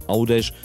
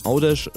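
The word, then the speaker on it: Għawdex: Audesh schreibt man übrigens Gavdex, G-H-A-W-D-E-X. Und das ist das ideale Beispiel für das, was ich eben schon mal versucht habe zu erklären. G-H fällt also ganz weg am Anfang.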